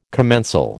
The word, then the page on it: commensal: American English